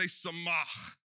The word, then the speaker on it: samach: All right, kara, it was at the front, samach, it's at the end, but you still got to hawk that loogie, okay? That's part of how you pronounce it correctly.